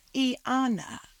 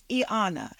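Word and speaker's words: Y'Anna: It's Iyana, like Iyanla, like Yvette. So the Y is pronounced as an E. The apostrophe separates the E to give you a little Selah moment and come in with the Ana.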